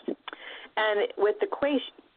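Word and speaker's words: quaich: I think it's a quaish, which it's a Gaelic word, so it's probably spelled, you know, like S-O-M-E or something. But it's pronounced quaish. I'm pretty sure that's how it's pronounced.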